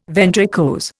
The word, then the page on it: ventricose: Indian